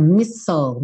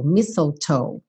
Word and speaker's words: mistletoe: Preste atenção a essa pronúncia, mistle, mistletoe, que é o visgo, aquela planta que normalmente se fazem as guirlandas ou que se deixam penduradinhas ali nos batentes da porta.